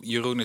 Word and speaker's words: Jeroen: Jeroen is probably the most common name in the Netherlands and the most impossible to pronounce abroad.